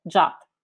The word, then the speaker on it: JWT: In English, I usually hear the pronunciation as JWT, but the RFC suggests the same pronunciation as the English word JUT.